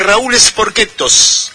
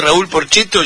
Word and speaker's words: Raúl Porchetto: Y Gino Reni decía, vos vado a cantar un tema de Raúl Sporquetos. ¿Quién? Le decía el Gordo. Raúl Sporquetos. Claro, lo pronunciaba en italiano. Y me causaba mucha gracia. Entonces, cada vez que escucho a Raúl Porchetto, yo enseguida en mi mente, Raúl Sporquetos.